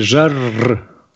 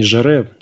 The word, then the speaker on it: Жарр: Правильно все-таки называть Жан-Мишель Жарр, 2 «р», потому что «е» в французском не читается, не «жаре», а «жарр», Жан-Мишель Жарр. Вот, я тебе ссылочку сбросил, чтобы ты мог послушать альбом его, может, что-то для себя отдельное найдешь.